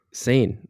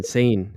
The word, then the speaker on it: seine: Sane. Like S-A-I-N. Sane. Sane? – S-A-I-N. – Yeah, but you spell it. No, that's how I'm saying it. Sorry. How I have it down for pronunciation.